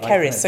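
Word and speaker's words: Cerys: Keris.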